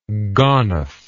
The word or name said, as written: gonif